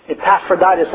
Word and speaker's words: Epaphroditus: But we do understand that it was counted as a sacrifice when Epaphroditus, I think, is the way you pronounce his name, sent, I guess, food to Paul.